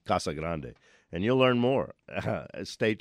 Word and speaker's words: Casa Grande: And we don't have words, Casa Grande, in English, so they have to be pronounced as the Spanish words they are. Their pronunciation, the correct pronunciation, is Casa Grande.